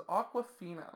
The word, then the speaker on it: Awkwafina: So the first cast member in this film is Awkwafina. – Yes, like the brand of water.